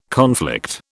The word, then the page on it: conflict: British English